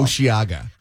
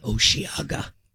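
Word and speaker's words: Osheaga: Oceaga. – Okay. – Oceaga.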